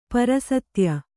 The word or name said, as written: ಪರಸತ್ಯ